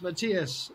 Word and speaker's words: Matías: Um, today, I am delighted to be joined by Rossana Ceccato and uh, uh, Matthias. Perfect. Perfect. practicing these names now. Rossana is from Venezuela. Mat- Matthias, Matthias, Matthias is from Matthias, Matthias Matthias. – Matthias. It's Matthias.